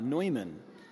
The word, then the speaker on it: Neumann: Now St. John Neumann, that's the German pronunciation, and his father was German, his mother was Czech, and he was born in the year 1811 in the Czech Republic.